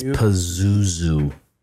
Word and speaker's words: Pazuzu: P-Z-U. – P-Z-U. – It's Pazuzu. P-A-Z-U-Z-U-Z.